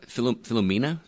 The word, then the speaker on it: Philomena: Philomena, did I pronounce it correct? – Yes, and that looks fantastic.